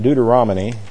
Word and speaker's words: Deuteronomy: but come back to the 28th chapter of Deuteronomy. Deuteronomy, or as my mother used to pronounce it, Deuteronomy.